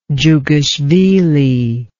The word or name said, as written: dzhugashvili